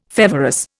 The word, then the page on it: feverous: Indian